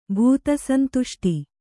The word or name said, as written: ಭೂತಸಂತುಷ್ಟಿ